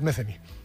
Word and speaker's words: Metheny: ¿Pat Metheny o Pat Metheny? – Metheny.